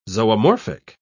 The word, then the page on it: zoomorphic: British English